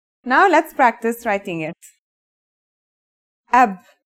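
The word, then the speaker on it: ab (أب): It's pronounced ab. This is the word for father in Arabic.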